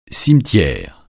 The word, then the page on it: cimetière: francais